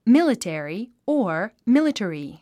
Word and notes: military: mílitèri; ミリテリィ

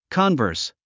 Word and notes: converse: kənvə́ːrs; コンバース

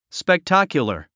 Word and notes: spectacular: spektǽkjulə r; スペクタァキュラァ

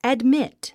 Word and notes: admit: ædmít; アドミット

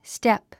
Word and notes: step: stép; ステップ

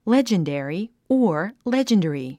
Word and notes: legendary: lédʒəndèri; レジェンデェリィ

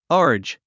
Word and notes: urge: ə́ːrdʒ; アージ

